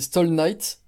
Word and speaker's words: Stahl Knight: Moi, je dis Stall Night, par exemple. Alors, c'est un mot anglais mélangé à un mot allemand. Stall, c'est simplement style, mais en allemand, quoi.